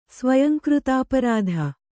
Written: ಸ್ವಯಂಕೃತಾಪರಾಧ

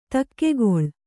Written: ದೊಗ್ಗಳೆ

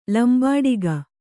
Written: ಲಂಬಾಡಿಗ

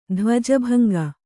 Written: ಧ್ವಜಭಂಗ

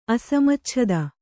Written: ಅಸಮಚ್ಛದ

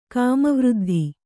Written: ಕಾಮವೃದ್ಧಿ

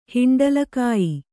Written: ಹಿಂಡಲಕಾಯಿ

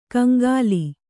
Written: ಕಂಗಾಲಿ

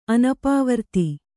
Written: ಅನಪವರ್ತಿ

